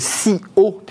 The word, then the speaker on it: CIO: Fenêtre sur l'emploi avec notre invité, euh, alors euh, le SEO, le CIO, puisque c'est bien comme ça que l'on prononce. – Le SIO, exactement.